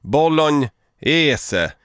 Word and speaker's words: bolognese: Bolognese. – Bolognese. Alltså att man ska uttala, man ska i Italien säger man bolognese. Så jag uttalar ju det på det italienska sättet.